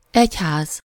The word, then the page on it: kerk: /kɛrk/